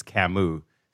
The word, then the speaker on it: Camus: Albert Camus, I don't think that's how you pronounce it. I think it's Camus, C-A-M-U-S.